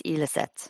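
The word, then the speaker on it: Ilestedt: Don't pronounce the T in the middle, so it's Eliset.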